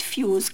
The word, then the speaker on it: fuse: To have a short fuse, كلمة fuse بنكتبها F-U-S-E, لكن هنا بننطقها الاس بتنطق ز.